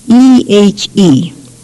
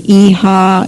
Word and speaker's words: ehe: Now, the little word yes, E-H-E, it's pronounced many, many ways in many areas, different areas. Some say e-ha, e-he, e-he. You know, we don't question. It's a minor thing. But the spelling of the word ehe is E-H-E.